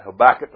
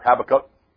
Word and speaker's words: Habakkuk: In Habakkuk, if you want to pronounce it like that, Habakkuk or Habakkuk, right?